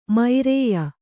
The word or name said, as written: ಮೈರೇಯ